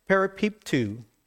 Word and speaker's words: peripipto: Now, the word fall into is really one word in the Greek, and it's pronounced peripipto, which means to be encompassed by or surrounded with.